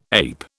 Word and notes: ape: British English

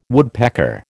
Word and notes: woodpecker: American English

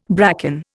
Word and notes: bracken: Indian

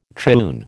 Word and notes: triune: American English

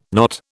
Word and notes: not: British English

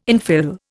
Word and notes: infill: Indian

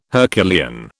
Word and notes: Herculean: British English

